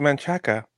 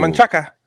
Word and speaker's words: Manchaca: And it says Manchaca, but they pronounce it Manchac. It's not Manchaca. It's Manchac.